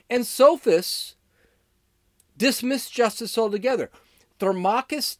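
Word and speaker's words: Thrasymachus: Uh, so Thermachus, that's how you pronounce it.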